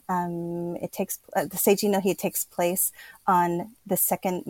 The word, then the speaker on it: Seijin no Hi: Even if just to witness something along the lines of, and I'm going to try and pronounce this, Seijin no Hi. – Oh, very good. That's exactly how it's pronounced.